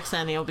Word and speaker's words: Xennial: I think it sounds better, Xennial, because you get Generation X, which is the X part, and then the Ennials of Millennials, so you smoosh it together, Xennials.